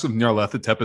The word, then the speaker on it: Nyarlathotep: There's like Masks of Nyarlathotep is the big one.